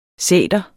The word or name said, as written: sæter